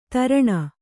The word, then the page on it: ತೀರ್ಣ: kannada